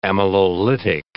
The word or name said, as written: amylolytic